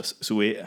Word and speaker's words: Suez: The Swiss? – No, Suez. How the fuck do you pronounce it? Suez? S-U-E-Z? – S-U-E-Z.